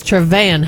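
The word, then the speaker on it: TreVeyon: It's not Trevaeon Henderson. It's Trevaeon Henderson.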